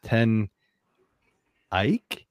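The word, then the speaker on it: Ten Eyck: So I'm going with 10 Ike Brewing Company. It's EYCK, as from Queenstown, Maryland.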